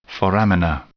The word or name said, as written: foramina